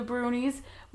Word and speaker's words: Brownies: And different countries have their own different versions of the Brunies, which is also spelled Brownies. So if I say Brownies, I mean the Brunies.